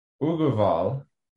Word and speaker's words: Aggarwal: and again it's it's pronounced Uguval, but spelled Aggarwal as in A G G A R W A L so Google that.